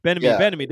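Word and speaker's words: Bieniemy: Is it Bennamy? How's it pronounced? – Bennamy. There you go.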